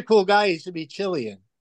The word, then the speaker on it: Cillian: I've only ever read Cillian.